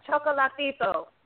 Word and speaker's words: Chocolatito: Yeah, Chocolito. – Right. – I'm saying the name right? Because I'm terrible with names. So it's Chocolatito. – Chocolatito.